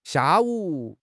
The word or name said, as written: xia4 wu3